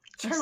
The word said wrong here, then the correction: chartreuse: Do you know the color charoust? I may be saying that wrong. – Spell it? – C-H-A-R-T-R-U-S. – Chartreuse. – Chartreuse.